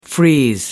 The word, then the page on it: freeze: American English